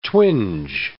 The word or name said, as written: twinge